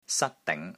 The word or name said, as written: SAT1 DING2